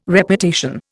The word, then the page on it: Reputation: Indian